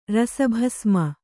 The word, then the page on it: ರಸಭಸ್ಮ: kannada